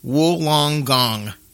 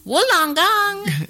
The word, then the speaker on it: Wollongong: Wollongong. – Wollongong. Wollongong. – That almost sounds dirty, and I'm ashamed that Daniel made me say it. So. – Well, I love it. I love a Wollongong.